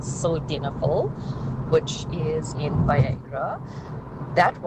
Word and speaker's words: Sildenafil: Sildenafil. Sildenafil.